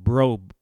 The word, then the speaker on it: Brough: And it's called Bro Brothers. But it is spelled B-R-O-U-G-H, Brothers, Bro Brothers. I went to their website and checked them out and everything. And their last name is Yarbrough. So it's Bro Brothers.